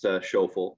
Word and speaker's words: Schoeffel: So my last name is Schoeffel, pronounced Schoeffel, very German.